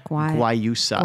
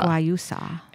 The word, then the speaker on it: Guayusa: Guayusa. – Guayusa.